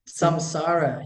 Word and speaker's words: samsara: Samsara? – Samsara, yes.